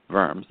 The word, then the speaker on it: Worms: That's 50 miles all the way to the city called Worms, Germany, or Worms, the W is pronounced like a V.